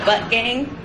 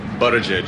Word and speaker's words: Buttigieg: Butt gang? Butt gang? – Yeah. – What's that? – Gang of butts. – How do you pronounce this? – Buttigieg.